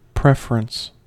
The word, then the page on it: preference: American English